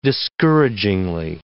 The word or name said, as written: discouragingly